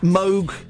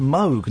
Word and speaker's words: Moog: Because he was the first ever Moog synthesizer in pop music was on a Monkees record. And it's pronounced Moog, not Moog.